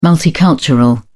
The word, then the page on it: multicultural: British English